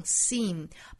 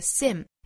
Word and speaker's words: seem: S-E-E-M 这个词读成 seem, 包含长音的紧音的 E 的发音，不要读成了 seem, 应该是 seem, 上下齿之间几乎不留间距。